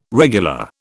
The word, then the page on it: regular: British English